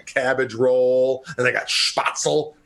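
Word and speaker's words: Spätzle: Spatzel, bing, ba-ding-ding-ding, spatzel, bing, ba-ding-a-little. I don't even know that's how you say it. – I think it's spetzel. – Spetzel. Mark for the win.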